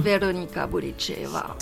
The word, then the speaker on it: Veronika Bulicheva: Veronika Bulicheva. – Voilà, comme vous prononcez bien. – Veronika Bulicheva.